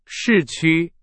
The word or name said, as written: 市区